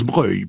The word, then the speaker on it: Broglie: Et eh oui, car si on dit Broglie pour la commune, on prononce Breuil pour le nom de famille.